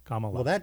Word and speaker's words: Kamala: Comma, and then add la on the end. – Comma. Comma la. – Yep. That's precisely how it's pronounced. Kamala.